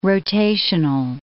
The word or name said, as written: rotational